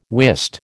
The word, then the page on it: wist: American English